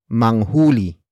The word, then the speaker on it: manghuli: The next word is manghuli. Manghuli. Manghuli. Manghuli is spelled as M A N G H U L I.